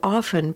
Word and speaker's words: often: And in the fourth column, the words have the f sound with the t silent. And generally, these words are pronounced often, soften, softener, softening.